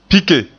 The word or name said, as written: pike